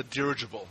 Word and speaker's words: dirigible: He's on the dirigible. Is that how you pronounce it? – Yes. Yes.